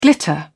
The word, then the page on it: glitter: British English